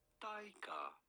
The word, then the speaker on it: Taika: We are looking at how to pronounce Taika. – Taika. Okay. Like Taika? – Yeah, like Thai.